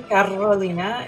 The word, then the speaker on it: Carolina: It's actually Carolina, if you can roll your R's.